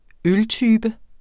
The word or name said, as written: øltype